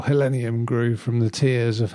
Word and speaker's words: Helenium: Yeah, just to pronounce it properly so we're clear with everyone, it's called Helenium morum beauty, common name Helen's flower.